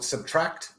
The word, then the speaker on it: SBTRKT: But it's actually an artist called Subtract, which is spelled SBTRKT, and the tune is called Wildfire, and I think it's a mashup with another singer called Little Dragon.